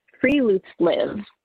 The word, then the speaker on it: friluftsliv: So, and I read this in National Geographic, and it is this Norwegian concept of friluftsliv. It's spelled differently than it sounds like I'm saying because it's Norwegian, but it's considered open air living.